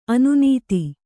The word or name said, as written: ಅನ್ನದ